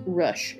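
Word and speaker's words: Rüsch: It's R with the U with the two little dots above it. S C H. So it's Rush. – The U is ooh. – It's like it's Rush.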